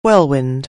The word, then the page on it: whirlwind: anglais